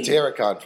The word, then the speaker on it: Satyricon: Satiricron won't release a new album until next year, 2017. – Satiricon. – Satiricon.